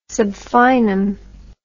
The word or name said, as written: sub finem